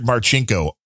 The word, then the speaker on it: Marcinko: Dick Marchinko. Richard Marchinko. – Spell it. – M-A-R-C-I-N-K-O.